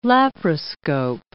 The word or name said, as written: laparoscope